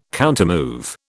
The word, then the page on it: countermove: British English